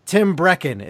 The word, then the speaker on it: Tim Breckon: Tim Breckon, is that right? – Yeah, that's right.